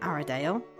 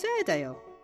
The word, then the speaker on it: Airedale: It isn't Arradale, it's Airedale.